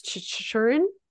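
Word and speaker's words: Chychrun: How do you say Jacob's name with the Yotes? Chikrin. Thank you.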